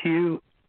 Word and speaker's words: Hugh: it's Hugh H U G H.